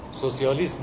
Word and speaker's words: سوسیالیسم: سوسیالیسم می‌گیم، سوشالیزم نمیگیم، کمونیسم میگیم، کامیونیزم نمیگیم.